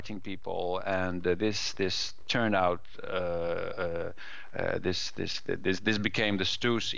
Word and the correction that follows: Stoos: event. Stoos, it is actually pronounced in German,